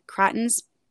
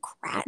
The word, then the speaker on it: craton: different cratons. See, that's a word I really don't know if I'm pronouncing correctly. It's C-R-A-T-O-N. Okay. And I've only ever heard one professor pronounce it, and he said craton.